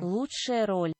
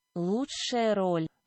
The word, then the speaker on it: Лучшая роль: Here is how you pronounce it in Russian. Лучшая роль. Лучшая роль.